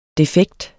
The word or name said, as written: defekt